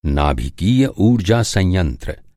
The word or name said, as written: संयंत्र